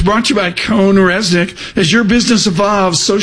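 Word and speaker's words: CohnReznick: On Jobs Day, we spell it Cone Resnick, CONREZNICK, Cone Resnick, and we thank them for true foundational support of the conversations we have.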